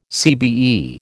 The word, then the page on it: CBE: American English